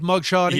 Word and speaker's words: mugshotties: You're familiar with mugshotties? – Yeah, I've seen mugshotties. Am I saying that correctly? Mugshotties? – Mugshotties, yeah.